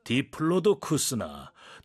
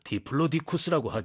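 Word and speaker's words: Diplodocus: 학명을 발음하는 방법은 두 가지인데 디플로도쿠스나 디플로디쿠스라고 하죠. 어느 쪽이든 좀 발음하기 어려워서 여기서 흔히 쓰는 애칭을 사용해야겠어요.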